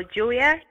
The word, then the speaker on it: Julia: So Julia, J-U-L-I-A.